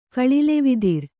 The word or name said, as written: ಕಳಿಲೆವಿದಿರ್